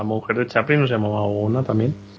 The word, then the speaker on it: Oona: Yo le digo Oona. – Oona. Oona. – Tal cual se lee.